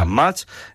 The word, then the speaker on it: Unmatched: Un matchet, sí. Este escrito literalmente, que es como lo pronuncia todo el mundo, es un matchet, ¿vale? Uh-huh. Unmatched.